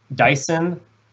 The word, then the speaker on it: Daesan: Park has been with Lotte Chemical since 1991 and is currently senior vice president with responsibilities for production at Lotte Chemical's Daesan, Ulsan, and Yeosu, South Korea complexes. My Korean's a little rusty. Hopefully I pronounced those cities correctly. – I think you did pretty well.